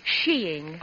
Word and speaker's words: skiing: By the way, it's pronounced sheeing.